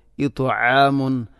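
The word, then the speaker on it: ഇത്ആമുൻ (إطعام): ഇത്ആമുൻ ഔ ഇത്, തഇനെ ഉച്ചരിക്കുമ്പോൾ നാവിൻ്റെ മധ്യഭാഗം അണ്ണാക്കിലേക്ക് ചേർന്ന് വരണം.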